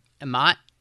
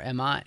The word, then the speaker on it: Emmat: I'm having trouble figuring out how to pronounce his name. – Emmat. – See, I was saying Emmat, like Emmett, Emmat, but with an A. – Emmat or Emmat, it's, yeah, either one.